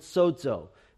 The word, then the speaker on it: sozo: And you have in both Luke and Acts the important Greek word sozo. You might spell it S-O-Z-O if you want to.